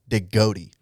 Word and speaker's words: Degatti: So I will check you on that because I had to double check and look at his last name, and he actually prefers to pronounce it Degatti. – Ooh. – So like the goat of all time.